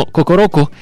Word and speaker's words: Kokoroko: Sí, Kokoroko, hay muchas K's y muchas O, pensé que lo había pronunciado mal, pero no, Kokoroko,